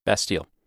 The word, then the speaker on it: Bastille: I know it's called the Bastille Day, Bastille. How do you say it? – Bastille. – Bastille. – So we'll say Bastille. – That's correct. It was it was seen as a symbol of the absolute monarchy's abuse of power.